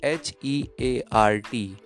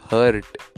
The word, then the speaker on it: heart: Next word is H E A R T. Correct pronunciation is heart. Mispronunciation is hurt.